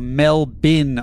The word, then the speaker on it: Melbourne: It's pronounced Melbourne or Melbin, Australia, not Melbourne, Australia.